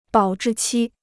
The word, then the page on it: 保质期: bǎo zhì qī